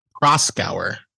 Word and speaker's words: Proskauer: So before this story, there was a story where there was Proskauer? How do you say it? – Proskauer. Yes. – Okay. I never heard somebody say it in conversation. – Proskauer.